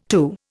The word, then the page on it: to: Indian